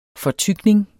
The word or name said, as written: FORTYKNING